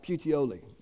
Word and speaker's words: Puteoli: And then after Regium, to Puteoli. That's how it's pronounced. Puteoli.